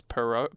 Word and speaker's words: Perrault: A Tchaikovsky ballet and the Sleeping Beauty by Charles Perrault. – How do you spell it? – P-E-R-R-A-U-L-T. – A-U-L-T. – Perrault. Yeah. Perrault.